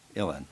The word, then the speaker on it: Uilleann: It's spelled U-I-L-L-E-A-N-N. People ask me how you pronounce it, and I say, how do Americans say Texas? I'll say Tennessee. How do they say New Orleans? So I just pronounce it Illen. But T-I-O-N-O-L is Chenal.